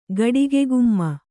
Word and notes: ಗಡಿಗೆಗುಮ್ಮ: gaḍige gumma

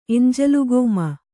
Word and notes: ಎಂಜಲುಗೋಮ: Kannada

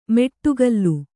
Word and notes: ಮೆಟ್ಟುಗಲ್ಲು: meṭṭugallu

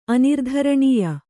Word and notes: ಅನಿರ್ಧರಣೀಯ: anirdharaṇīya

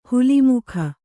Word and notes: ಹುಲಿಮುಖ: huli mukha

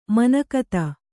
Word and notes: ಮನೋಗತ: manōgata

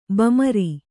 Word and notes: ಬಮರಿ: bamari

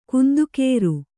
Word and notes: ಕುಂದುಕೇರು: kannada

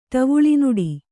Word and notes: ಟವುಳಿನುಡಿ: kannada

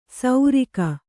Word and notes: ಸೌರಿಕ: kannada

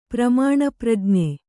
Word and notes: ಪ್ರಮಾಣಪ್ರಜ್ಞೆ: pramāṇa prajñe

